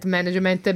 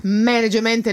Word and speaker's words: management: Management, non management, perché mi hanno scritto che lo devo pronunciare correttamente. – Dimmelo bene. – Management. – Management. – No, management, con l'accento sulla prima A, scusate.